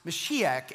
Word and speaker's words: Mashiach: It's a Hebrew word, obviously within the Old Testament, meaning pronounced Mashiach.